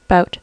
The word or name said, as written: bout